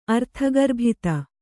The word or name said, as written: ಅರ್ಥಗರ್ಭಿತ